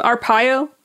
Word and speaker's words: Arpaio: Arpaio.